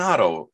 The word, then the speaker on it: Otto: No, it's Glenn Otto. You say his name the same backwards and forwards, just the way he pitches, too.